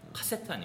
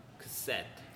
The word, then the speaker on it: cassette: 지금 뭐 쓰실 일은 없지만 우리 외래어로 카세트라고 얘기하는데 지금 저희가 발음한 것처럼 cassette 이쪽 set의 강세가 있어요. 카세트 아니고 cassette.